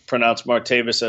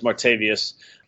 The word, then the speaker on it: Martavis: mispronounce Martavis as Martavius over the course of the next 2 months, because it's a topic where there's nothing to add.